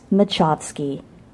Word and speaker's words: Machovsky: Julie called him machos for short, like nachos, even though their creator's surname was pronounced Machovsky. Lance Machovsky.